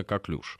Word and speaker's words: коклюш: В принципе, можно так и так, но более правильно коклюш.